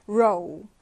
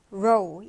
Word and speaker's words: row: 이거 발음 주의해서 읽어볼까요? row, row 이렇게 오 발음으로 읽어주는데요.